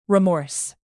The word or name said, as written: remorse